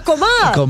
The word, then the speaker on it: comal: How do you say it right? Huh? – A comal. – A comal. – C-O-M-A-L.